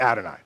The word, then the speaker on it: Adonai: When these four letters are together, you pronounce it Adonai. Okay, a little weird. It's Jewish tradition. You don't pronounce the name of God.